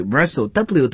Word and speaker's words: wrestle: 大家注意这个词啊，W-R 开头。那么往往有时呢，这个 W 是不发音的。比如说这个吹哨子 whistle，啊，像这里 wrestle，W 都是不发音的，在英语中被称作 unvoiced，啊，不发音。